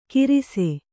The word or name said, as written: ಕಿರಿಸೆ